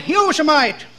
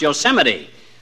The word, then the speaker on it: Yosemite: Had a job on a ranch out there for a while up near that state park, Yosemite. – No, not Yosemite, old-timer. That's pronounced Yosemite.